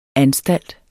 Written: anstalt

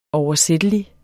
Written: oversættelig

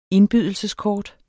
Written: indbydelseskort

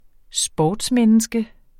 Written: sportsmenneske

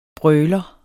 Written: brøler

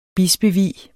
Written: bispevie

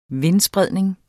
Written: vindspredning

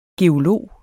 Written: geolog

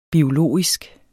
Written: biologisk

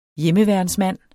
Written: hjemmeværnsmand

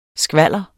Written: skvalder

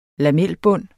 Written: lamelbund

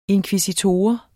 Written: inkvisitor

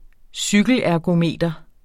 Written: cykelergometer

